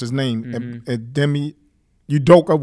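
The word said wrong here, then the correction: Ime Udoka: whatever his name is. – M.A. Udoka. – Thank you, sir.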